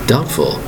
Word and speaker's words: doubtful: Very uncertain of things to happen is called doubtful. D-O-U-B-T-F-U-L. Remember, the B is silent in this word. Spelled D-O-U-B-T-F-U-L.